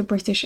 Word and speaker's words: British: So you've probably seen on the internet sometimes this kind of joke that we pronounce British as British. Like, you can hear it the way that I say it, that I don't pronounce this T very hard. I don't say British.